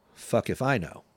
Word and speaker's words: Fukiphino: Beer is spelled F-U-K-I-P-H-I-N-O, pronounced fuck if I know.